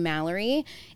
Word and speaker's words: Malerie: Spell it M-A-R-U. How do you spell it? – It's M-A-L-E-R-I-E. If you go anywhere and you have like those little name tags or trinkets or anything with the name Malerie, it's consistently M-A-L-L-O-R-Y.